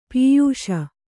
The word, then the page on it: ಪಯಸ್ಸು: payassu